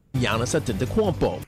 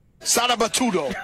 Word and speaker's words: Antetokounmpo: Giannis Antetokounmpo.